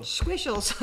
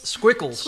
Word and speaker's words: Squickles: So squishles. Or how do you say that? Squishles? – Squickles. – Squickles. – Yeah, that's right.